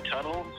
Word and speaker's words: Tuttle: I mean, you can just look up my name, Eric Tuttle, on Apple Music or Spotify. That's Eric spelled with a K. And Tuttle, T-U-T-T-L-E.